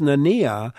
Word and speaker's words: Nania: His name is pronounced Nania, and it's spelled N-A-N-I-A, N-A-N-I-A.